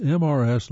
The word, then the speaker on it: Mrs.: And why do you pronounce Mrs., MRS, like Mrs., which is conspicuously missing an R?